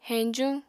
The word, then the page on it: heñjuñ: Apatani